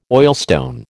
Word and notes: oilstone: American English

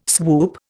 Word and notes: swoop: Indian

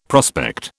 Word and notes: prospect: British English

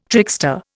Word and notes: trickster: Indian

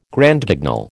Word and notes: Grand Guignol: American English

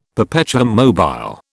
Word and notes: perpetuum mobile: British English

